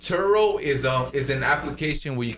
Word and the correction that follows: Turo: How do you spell that? – T-U-R-O. – Oh, Toro. Turo.